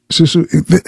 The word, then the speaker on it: susu: He's telling us about Susi. Am I pronouncing it correctly? – Susu, this is what they do, the African brothers and sisters do.